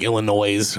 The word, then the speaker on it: Illinois: And then that when people put the S, the actual S noise on the end of that silent S, like Illinois or.